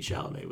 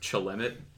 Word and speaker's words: Chalamet: We have Timothy Chalamet with us. – It's actually pronounced Chalemet.